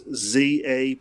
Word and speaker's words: Zapier: I also use a website called, I think you would pronounce it Zapier, unless they're French and it may be Zapier, but it's Zapier.